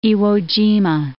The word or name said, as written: Iwo Jima